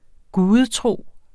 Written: GUDETROS

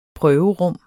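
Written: PRØVERUM

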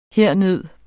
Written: herned

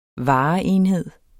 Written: vareenhed

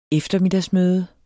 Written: eftermiddagsmøde

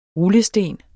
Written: rullesten

